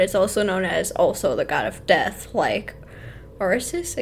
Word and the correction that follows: Osiris: I can't say his name correctly. Osiris. – Osiris. Thank you.